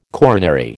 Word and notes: coronary: American English

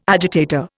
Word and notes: agitator: Indian

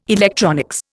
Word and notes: electronics: Indian

